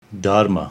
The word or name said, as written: Daharma